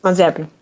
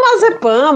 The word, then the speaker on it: Mazepin: Aí esses é que irão entrar, principalmente Mazepin. – Falei certo? Perto do certo? – Mazepin. – Mazepin. – Mas fala Mazepan mesmo, todo mundo fala Mazepan, fala Mazepan.